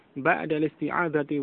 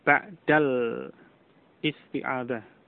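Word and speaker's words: بعد الاستعاذة: Ba'da listi'adati wal basmalati. Ba'da listi'adati. Ba'da listi'adati. Kenapa diucapkan seperti itu? Karena hamzah pada isti'adah itu hamzah wasal. Jadi yang benar pengucapannya bukan al-isti'adah. Bukan al-isti'adah. Bukan ba'dal isti'adah.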